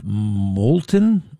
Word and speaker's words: Moulton: The number one reason why Seth Moulton, nobody knows how to say his name, M-O-U-L-T-O-N. If he were smart, he would have changed it a long time ago. He would have said M-O-L-T-E-N, Moulton, like molten lava. Wow, that's a kick-ass kind of guy. Seth Moulton?